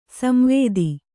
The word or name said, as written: ಸಂವಾದ